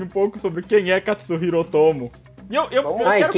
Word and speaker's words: Katsuhiro Otomo: E só depois que eu fui aprender que a pronúncia correta é Katsuhiro Otomo e não tem um S ali bom, onde eu achava.